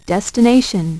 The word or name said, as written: destination